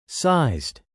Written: sized

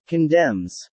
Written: condemns

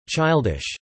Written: childish

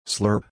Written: slurp

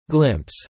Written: glimpse

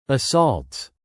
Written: assaults

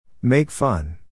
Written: make fun

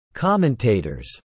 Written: commentators

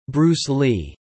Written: Bruce Lee

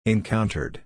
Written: encountered

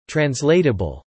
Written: translatable

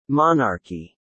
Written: monarchy

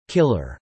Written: killer

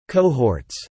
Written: cohorts

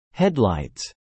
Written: headlights

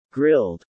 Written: Grilled

